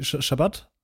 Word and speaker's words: Schabbat: Also ich glaube, man sagt in Deutschland, in Deutschland sagt man irgendwie Sabbat, aber es das heißt nicht Sabbat, oder? Es das heißt irgendwie Schabbat? – Genau, also wenn man es hebräisch ausspricht, heißt es Schabbat, genau.